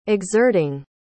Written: exerting

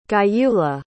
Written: Gyula

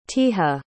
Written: teja

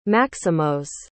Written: maximos

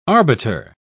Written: arbiter

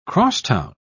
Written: crosstown

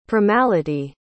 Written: primality

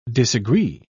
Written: disagree

